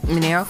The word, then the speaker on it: Mineo: Yes, Mineo.